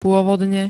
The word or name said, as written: pôvodne